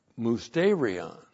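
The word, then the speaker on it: musterion (μυστήριον): Point five, remember that the church age is musterion. This is the Greek word, M-U-S-T, long E-R-I-O-N, musterion.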